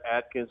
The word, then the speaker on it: Adkins: I just tell people, if you just Google Brett Adkins, and it's spelled A-D-K-I-N-S, or if you even put the name Adkins in there, it's probably going to pop up on the homepage.